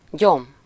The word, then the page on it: weed: /wiːd/; American English; [wiːd]